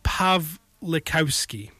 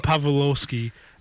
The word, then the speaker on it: Pawlikowski: Pavel Pawlikowski. – Yes. – There's W's in there, but there's enough Polish people in my life to know that that's probably pronounced with a V. Pavel Pawlikowski, who directed Fatherland.